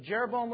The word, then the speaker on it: Jeroboam: And initially, the northern kingdom was ruled by a man named Jeroboam. Rhymes with Rehoboam. But Jeroboam was a bad eye.